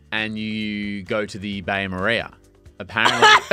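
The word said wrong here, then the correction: bain-marie: So apparently I've been saying this wrong the whole time. – Yeah, Bain Marie.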